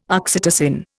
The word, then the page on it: oxytocin: Indian